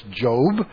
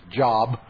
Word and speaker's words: Job: We know as Job. Early in your Christian experience, you might have thought his name was Job, but it's pronounced Job.